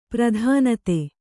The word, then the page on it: ಪ್ರಧಾನಿತಿ: pradhāniti